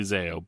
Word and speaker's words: Zao: probably don't know how to pronounce correctly. But Zao. – It's definitely Zayo.